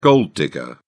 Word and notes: gold digger: British English

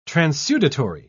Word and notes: transudatory: British English

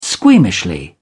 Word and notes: squeamishly: British English

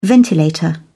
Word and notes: ventilator: British English

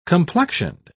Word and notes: complexioned: British English

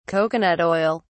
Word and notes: coconut oil: British English

